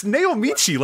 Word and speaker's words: Naomichi: It's Naomichi.